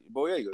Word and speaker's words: Boyega: But um, yeah, I think Colman Colman probably walk away with the hardware on that, but Boyega, I hope I'm saying his name right. Boyega, uh, Boyega, whatever. – How you? No, you had it right. Boyega.